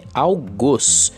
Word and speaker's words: algoz: A forma correta de falar é algoz. Isso mesmo, pessoal, com o O fechado, aquele que tem um som com um acento circunflexo, o famoso chapéuzinho que você aprende lá no Fundamental 1, tá?